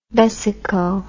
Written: vesico

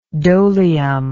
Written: dolium

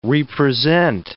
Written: re-present

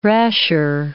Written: rasher

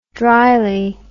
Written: dryly

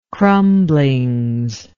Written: crumblings

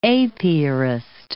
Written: apiarist

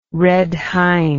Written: red hind

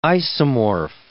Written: isomorph

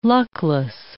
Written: luckless

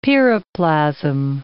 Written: piroplasm

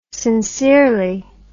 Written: sincerely

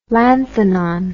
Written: lanthanon

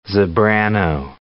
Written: zebrano

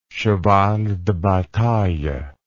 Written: cheval de bataille

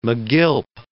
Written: megilp

